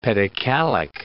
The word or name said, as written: pedocalic